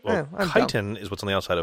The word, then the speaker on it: chitin: The hard shell, also mispronounced as chitin. – Ah. – Much like lichen is also possibly lichen sometimes.